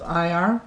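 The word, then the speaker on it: Iyar: If somebody would like to have the calendar for May, or it's the month of IR, is that how you pronounce it? – Mm-hmm.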